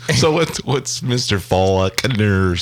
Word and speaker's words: Faulkner: This week's quip or quote is from William Faulkner. – I don't think you pronounce the L.